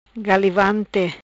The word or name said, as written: Galivante